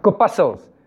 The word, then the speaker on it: corpuscles: Is the, how do you pronounce that? Corpuscles. Is it? Corpuscles.